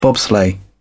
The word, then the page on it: bobsleigh: British English